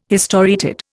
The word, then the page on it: historiated: Indian